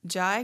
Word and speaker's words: Jai: And it's also pronounced, I've heard it pronounced J. So I just say, it sounds better saying Jai Guru Dev to me. Sometimes the J sounds better with other words, but Jai and J are, I believe, the same thing.